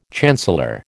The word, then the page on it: chancellor: American English